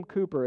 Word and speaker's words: Cowper: William Cooper, it's spelled C-O-W-P-E-R.